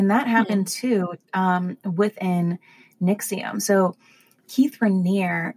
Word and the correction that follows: Raniere: or sorry, Ranieri, that's how you pronounce it.